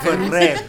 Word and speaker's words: Ferrero Rocher: You know you gotta roll the R like our Ferrero Rocher.